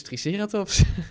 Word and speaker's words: Triceratops: Nou, dat is dus Triceratops. maar dat is dan de Engelse klemtoon.